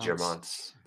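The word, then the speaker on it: Guermantes: So part three uh, how do you pronounce this? The the Germonts or the Germonts way? I don't know. Germontes, Germonts. – Germonts.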